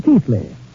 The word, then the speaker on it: Keighley: The Brontes lived in Yorkshire, England, in the 19th century, not far from my ancestral town of Keighley, pronounced in England, Keithley. Although how they made Keithley out of K-E-I-G-H-L-E-Y, I'll never know.